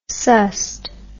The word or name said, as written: sussed